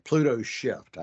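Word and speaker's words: PlutoShift: like at PlutoSoft. Pluto Shift. I said it wrong.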